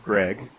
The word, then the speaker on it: Gregg: Look up my name, Steve Gregg. My last name is spelled G-R-E-G-G, Steve Gregg, and put in the word Zeitgeist. It's Z, as in zebra, E-I-T-G-E-I-S-T, Zeitgeist.